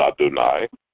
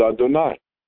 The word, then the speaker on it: Adonai: And so you can be reading a prayer, and you'll see the word Aleph, Dalet, Nun, Yud, and you'll pronounce it Adonai. And you'll go along, and you'll see Yud, Hey, Vav, Hey, and you'll pronounce it Adonai.